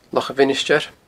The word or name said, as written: Loch a' Mhinisteir